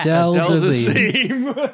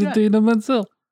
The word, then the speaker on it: Idina Menzel: I've purely cast this film just to make it so that in the future he does pronounce her name correctly because he's been in a film. – I worked with her on Grease 2. Her name's Idina Menzel.